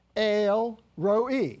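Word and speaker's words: El Roi: And so if you go to the Blue Letter Bible, you'll see ale, as in that, you know, that fermented beverage, ale, roe. That's how it's pronounced in Hebrew.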